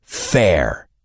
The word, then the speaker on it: fair: Fair se pronuncia fair, y su opuesto es unfair, injusto.